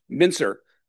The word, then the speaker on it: Mincer: Our first speaker, Irving from UTLP, or UT El Paso, is going to talk about, is it Mincer? Mincer, soft C.